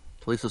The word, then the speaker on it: Talisa: Is it Talisa? Talisa? – Talisa Soto, yeah, Katana.